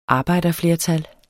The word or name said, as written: arbejderflertal